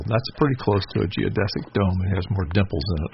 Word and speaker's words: geodesic: And so he is the inventor of the geodesic dome. Did I say that right? – Yeah, sure.